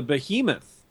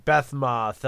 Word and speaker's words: Behemoth: I always pronounced it Beth-moth. I'm like, it doesn't look like a moth at all to me.